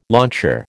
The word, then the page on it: launcher: American English